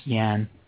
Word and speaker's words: Yan: Yan or Yan? – I think it's pronounced Yan. You're probably right.